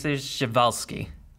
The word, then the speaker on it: Przewalski: So, typical correct pronouncing is Przewalski. – Oh, Przewalski. I've never heard anyone say it that way. – Yeah. It's Russian, so it's weird.